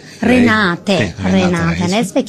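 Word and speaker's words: Renate Reinsve: Renata Rensve, si pronuncia così? – Renate Rensve, che ascolterete a breve.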